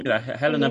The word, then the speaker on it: Helena: Was it Elena Bonham? – Yeah, Helena Bonham Carter. – Helena, that's it. Elena. I never know how to say her name.